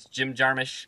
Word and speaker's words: Jim Jarmusch: It's Jim Jarmusch for Stranger Than Paradise.